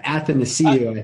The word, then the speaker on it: Athanasiou: Anastasiou. – Athanasiou, I think that's how.